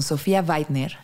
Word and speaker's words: Weidner: Hoy estamos con Sofía Weidner.